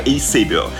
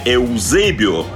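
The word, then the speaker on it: Eusébio: Звонкое имя этого португальского клуба Бенфика исторически неизбежно связывают с именем его ярчайшего нападающего Эйсебио. Вообще-то правильно Эузебио, но тут уже мы не будем настаивать на произношении, как нам его представил на чемпионате мира 66 года Николай Озеров, так мы и привыкли говорить.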